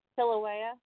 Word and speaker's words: Kilauea: How do you say the volcano name? – Kilauea. – Kilauea. Okay, sorry, I was butchering that too.